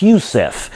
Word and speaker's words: Yusuf: So he was handpicked, just like Joseph, in a matter of speaking, or correctly pronounced Yusuf.